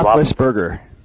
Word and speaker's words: Roethlisberger: I forget how you say his name, Roethlisberger. – Roethlisberger.